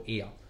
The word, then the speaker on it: Oia: So there's a place in Santorini, it's called Ia, right? It's spelled OIA, right?